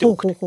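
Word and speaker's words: 晩生: はい、和瀬は早く生まれると書いて和瀬で奥手は晩に生まれると書いて奥手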